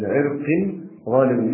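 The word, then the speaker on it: لعرقٍ ظالمٍ: والرواية كما يقول ابن الأثير: ليس لعرق ظالم الحق، يعني هكذا جاء في الرواية ولا تنطق ليس لعرق ظالم الحق لكن لعرق ظالم بالتنوين،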